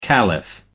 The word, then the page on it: caliph: American English